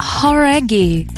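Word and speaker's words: Jauregui: É Lauren Horaghi. Talvez você já tenha ouvido falar dela, ex-integrante do grupo Fifth Harmony. – Horaghi. – Horaghi. Então a gente estava falando errado, cara.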